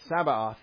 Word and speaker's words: Sabaoth: It's Lord Sabaoth. Sabaoth. How do we say it? Sabaoth. And I bet you read that and you're like, oh, it's supposed to say Sabbath. It's a typo, but it's not.